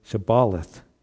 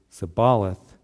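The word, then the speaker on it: shibboleth: And if he said, no, then they would say to him, then say, Shabaleth. And he would say, Sabaleth, and for he could not pronounce it right.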